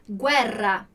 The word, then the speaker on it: guerra: Então, se você pensar bem aqui, quando a gente diz GUE, eu estou pronunciando o G, U e o E, né? Então, isso é legal porque pode ajudar a lembrar da pronúncia. Então, guerra, a gente não vai dizer guerra, vai dizer guerra, guerra, ok? Então, pronunciando ali o G, U, E dessa forma.